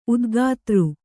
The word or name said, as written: ಉದ್ಗಾತೃ